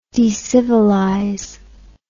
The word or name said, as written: decivilize